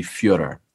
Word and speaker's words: Fyodor: You know, it's actually Fyodor.